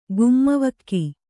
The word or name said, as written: ಗುಮ್ಮವಕ್ಕಿ